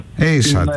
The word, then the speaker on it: ASAT: Πώς είναι η προφορά σωστή της ομάδας αυτής της αεροδιαστημικής? – ASAT. – Άρα καλά το είπα.